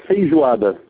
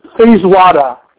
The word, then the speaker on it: feijoada: Pronounce that word again. Say that again. – We call it feijoada. – Feijoada. – Feijoada. Yeah, this is a national dish.